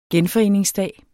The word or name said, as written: genforeningsdag